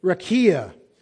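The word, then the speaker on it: rakia (רקיע): I want to encourage you to use those resources and to study out this word, rakia. Rakia. Now, someone's ability to enunciate Hebrew may far exceed my own, so I'll spell it for you. R-A-Q-I-A.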